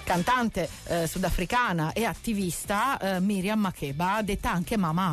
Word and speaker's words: Makeba: Su Radio Marte, beh, bisogna spostare l'accento. In realtà lei si chiama Makeba perché sa che questa canzone è dedicata a Miriam Makeba.